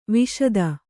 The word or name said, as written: ವಾಶಿತ